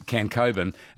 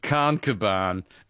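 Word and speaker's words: Khancoban: Well, my father, we broke down in New South Wales at one stage, and we were going into Cancoban, and he pronounced it Carncoban.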